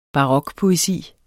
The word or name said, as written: barokpoesi